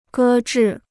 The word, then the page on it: 搁置: gē zhì